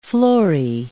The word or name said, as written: florae